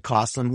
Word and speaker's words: Coslin: Thank you, Mr. Ralph and Mr. Coslin, who rhymes with awesome.